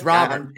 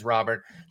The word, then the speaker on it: Robert: It's Robert. To Robert. Okay. And, you know, I just hope we just stick with this. I feel bad because I think it actually is Robert. And he asked people to pronounce it that way. I feel like people were on board and now it's back to Robert. The official pronunciation guide from the White Sox says Robert.